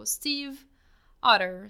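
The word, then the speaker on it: Stiefvater: According to her own website, it's pronounced like Steve, like the name Steve, and Otter, like the animal. So Steve Otter, Steve Otter.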